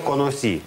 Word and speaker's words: conocí: Yo conocí. Вот здесь мы ставим ударение, потому что если бы не было здесь ударения, то читалось бы «конос». А нам надо, чтобы звучало «yo conocí», «yo trabajé», «yo vi».